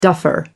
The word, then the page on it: duffer: American English